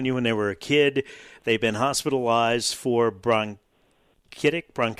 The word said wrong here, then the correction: bronchitis: however you pronounce bronchitis.